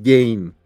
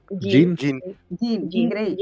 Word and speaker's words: Jean Grey: Félix también nos recuerda que Jean Grey, por alguna razón, en el intro le decían Jane. Ah. Jane. – ¿Cómo se dice si no? – Jean Grey.